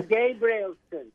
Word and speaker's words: Gabrielsson: Now I'm Gabrielsson. It's a Swedish surname.